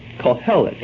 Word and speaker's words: Koheleth: And the word translated preacher in Hebrew is pronounced Kohelet, K-O-H-E-L-E-T-H, Kohelet.